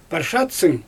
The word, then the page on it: parshatsyng: Xyzyl